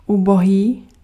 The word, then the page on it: misérable: /mi.ze.ʁabl/; France